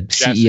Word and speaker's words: Jassy: We'll get to uh, them in just a second, but the way they uh, structured it was I guess is Andy, and I'm always say his last name wrong. Jaffe, is that right? Jaffe, you know the Jassy. CEO of Jassy.